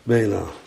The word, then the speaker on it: Bela: Bela met een Ayin aan het eind. Zeg ik dat goed? Ja. Dat wordt dus vanzelf een G. Van een NG wordt het een G. Bela.